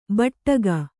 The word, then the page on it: ಬಟ್ಟಗ: baṭṭaga